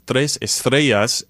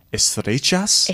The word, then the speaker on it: estrellas: What we do differently in Argentina and Uruguay as well is whenever we have a double L or a Y, we pronounce it as a sh sound instead of the E sound, like estrellas and estrechas, right? – Estrechas.